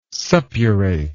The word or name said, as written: suppurate